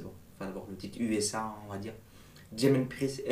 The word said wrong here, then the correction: Damien Priest: est champion, Damien Priest, je répète, je le dis bien,